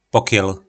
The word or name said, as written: pokjyl